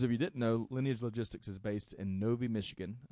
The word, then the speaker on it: Lineage Logistics: First off, as reported by the Wall Street Journal, Lineage Logistics, I think I'm pronouncing that right.